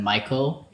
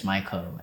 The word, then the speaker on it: Maiko: My handle is just Michael, J-U-S-T, just, and then Michael is M-A-I-K-O. It's just like a special way of saying my name because Michael is really normal. – Yeah. – So just Michael, M-A-I-K-O.